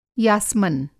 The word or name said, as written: यासमन